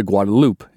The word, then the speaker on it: Guadalupe: She, her husband, her brother, and her two small children had been traveling to the site of their new homestead on the Guadalupe River, commonly pronounced the Guadalupe in Texas.